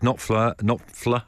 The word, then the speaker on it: Knopfler: Mark Knopfler. Oh, don't normally pronounce the F like that, do you, really?